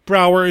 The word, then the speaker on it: Brower: Brower is spelled exactly the way it sounds, B R O W E R.